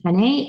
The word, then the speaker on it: Henne: And then I love a company called, I think it's pronounced Henne, H-E-N-N-E.